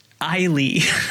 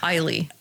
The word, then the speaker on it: Eilidh: E-I-L-I-D-H. – Oh, no, I can't. – I looked it up. And you will not believe how to pronounce it. – Oh, tell me. Please do tell. – Eile. – Yeah, see? Yes. Eile. – I love it. Why? – Yeah. Every Irish name is the cutest name. It's spelled not with letters. Not phonetically. It's the opposite. – Why is the D and the H completely silent? – Yeah.